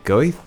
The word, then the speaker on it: Goethe: Goethe? I think that's how you say it. Goethe or Goethe. You might say it like that. G-O-E-T-H-E, Goethe.